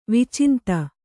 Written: ವಿಚಿಂತ

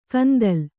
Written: ಕಂದಲ್

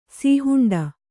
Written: ಸೀಹುಂಡ

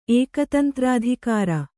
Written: ಏಕತಂತ್ರಾಧಿಕಾರ

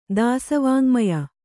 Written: ದಾಸವಾಙ್ಮಯ

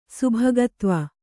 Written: ಸುಭಗತ್ವ

